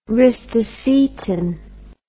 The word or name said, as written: ristocetin